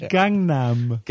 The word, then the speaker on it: Gangnam: Yes, it's Gangnam. – Gundam. – G-A-N-G-N-A-M.